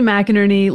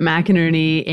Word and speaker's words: McInerney: Yeah, Lindsay McInerney, like Burton Ernie, but McInerney. And it's a heck of a one to pronounce.